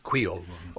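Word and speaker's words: Kwéyòl: So it's Creole, not Creole, it's Creole.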